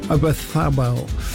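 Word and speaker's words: Oyarzabal: Lamine Yamel, Olmo, Bayena and Ozabalaf is up front. I didn't say that right, did I? I know. Obathabel.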